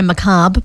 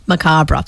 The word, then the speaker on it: macabre: Now, some people do say macabre, but the correct Google pronunciation is macabre.